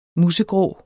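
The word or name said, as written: MUSEGRÅ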